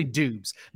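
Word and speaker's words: Doubs: And, like, if you ever watch film or, like, research these prospects, like, Christian Watson, even having second-round capital to Romeo Dubs, it's pronounced Dubs if you look at Google – but he said he just never corrected people. So it's actually Dubs.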